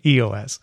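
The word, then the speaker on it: /e/OS: Yeah, they're not pronounced though. So we're just going to say EOS.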